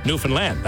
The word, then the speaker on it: Newfoundland: And that's how you say it. Understand Newfoundland.